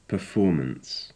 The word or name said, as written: perfomance